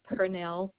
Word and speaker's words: Pernell: Last name is Pernell, P-E-R-N-E-L-L.